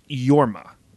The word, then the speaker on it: Jorma: FYI, J-O-R-M-A is pronounced Yorma, not Jorma. Apparently, I said Jorma, which I feel real stupid about because looking at that, I know it's Yorma.